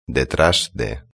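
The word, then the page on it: detras de: European Spanish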